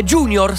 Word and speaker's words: junior: Junior, scritto con la J. In realtà è sempre anche quello del latino, ragazzi, che vuol dire giovane, e si pronuncia junior ed è latino.